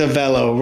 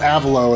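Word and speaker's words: Avello: Okay, I hate to be a smartass, but I thought it was a velo, like hello. – It is a velo, rhymes with yellow. – All right. So we got that straight as well. So it's not Avalo, it's a velo.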